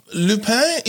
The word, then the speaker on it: Lupin: So Lupin is the way that I will choose to pronounce this to stay faithful to the language. But you may have heard it referred to as Lupin or Lupin or something, but it is actually Lupin.